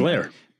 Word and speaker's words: Blahar: Jeff Blahar, I always mispronounce his last name. – Blair. Spelled Blahar. – If you're going to spell it Blahar, pronounce it Blahar, motherfucker.